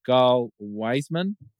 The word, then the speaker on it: Gal Weizman: That is Gal Weizman.